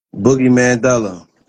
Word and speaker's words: Boogie Mandela: Boogie Mandela.